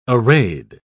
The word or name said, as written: arrayed